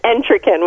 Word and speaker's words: Entraken: Now, of course, I can say Allison and I can say Weiss, but is it Entraken? – Yes, it's Entraken, with an emphasis on the first syllable, Entraken.